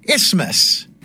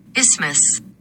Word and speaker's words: isthmus: brush up on my pronunciation of the word isthmus, right? Is that right, Siri? – Isthmus. – Wait, one more time. – Isthmus. – Isthmus. There we go.